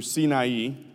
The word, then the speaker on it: Sinai: It's pronounced in Hebrew Sinai.